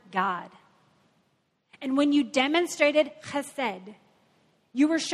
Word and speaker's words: chesed: Chesed. You like that? Chesed means loving kindness.